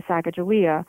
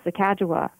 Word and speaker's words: Sacagawea: And I don't quite understand, and the common pronunciation of her name is Sacagawea, but the appropriate Shoshone pronunciation is Sacagawea.